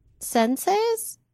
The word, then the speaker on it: senseis: She'd been taking karate and he was one of the senseis.